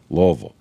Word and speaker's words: Louisville: Louisville. Is that how you say it? – Sometimes it actually sounds like Louisville. – Louisville.